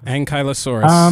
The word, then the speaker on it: Ankylosaurus: Ankylosaurus.